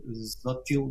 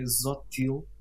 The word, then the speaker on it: Xochitl: É, ela, ela tem um nome, o primeiro nome é difícil de pronunciar, eu tentei obter eh, o nome correto e acho que é Zotil, é, mas escreve-se com X-O-C-H-I-T-L.